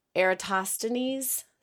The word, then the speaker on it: Eratosthenes: And so I strategically pick students to go in the hallway and I pull on Eratosthenes sieve.